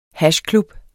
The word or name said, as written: hashklub